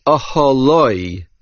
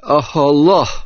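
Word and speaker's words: Aholoi (אהלה): The Kri, the pronunciation, is Aholoi, his tent, even though there is a He at the end of the word. It is pronounced with a Cholam. But according to the Ksiv, the spelling would be Aholoh.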